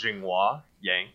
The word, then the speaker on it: Zhenghua Yang: So I guess the Americanized pronunciation of my name is Zhenghua Yang, and but it's just way too long.